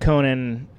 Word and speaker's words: Conan: And the reason why is because I've pretty much been fighting with people like, no, my name's pronounced Conan. Oh, yeah, Conan. That's not correct.